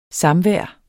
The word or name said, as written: samvær